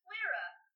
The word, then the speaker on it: Flira: This is Flira. This is my, fiery, familiar. – It's like Flaming Kira.